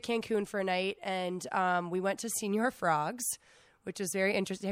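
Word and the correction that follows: Señor: No, it's senor.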